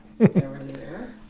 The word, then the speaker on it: Serraillier: by Ian Serralier. – Yes. – Sorry if I mispronounced that. – Mm-hmm. S-E-R-R-A-I-L-L-I-E-R. Yeah. Serralier, you said it better than me. – Serralier.